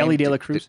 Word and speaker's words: De La Cruz: Ellie De La Cruz.